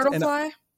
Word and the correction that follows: Brundlefly: I also, Brundlefly.